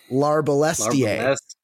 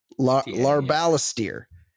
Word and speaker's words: Larbalestier: But what about Sir Lewis Larbalest? I can't even pronounce it, the new name. It's rude of him. Larbalestier. Larbalestier.